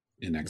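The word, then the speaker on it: inXile: It was Inzilli? – In Exile. – Is that how you pronounce it? Exile? In Exile? Oh, that would make, Inzilli doesn't make any sense. In Exile makes a lot of sense.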